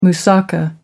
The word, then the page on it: moussaka: American English